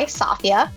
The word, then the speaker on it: Safia: And joining us today on this conversation is I, Safia, rhymes with mafia.